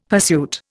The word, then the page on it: pursuit: Indian